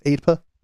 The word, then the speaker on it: AEDPA: commonly referred to as AEDPA. Is that how it would be pronounced? AEDPA? – That's fine.